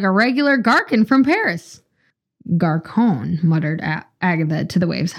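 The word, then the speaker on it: garçon: Garcon. – Oh, yeah. – I don't think I did that quite right. – Criminy. – Definitely a soft C.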